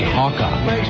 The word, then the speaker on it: Oaxaca: Apparently that Mexican city is Oaxaca. That's what it is. Scotty's typing it phonetically on the screen. Oaxaca.